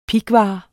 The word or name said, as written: pighvar